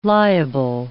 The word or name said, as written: liable